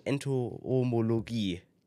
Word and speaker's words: Entomologie: Heute bei uns zu Gast Frau Dr. Lutz der forensischen Entomologie.